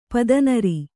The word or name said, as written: ಪದನರಿ